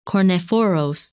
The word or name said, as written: Kornephoros